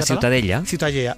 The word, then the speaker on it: Ciutadella: La Ciutadella.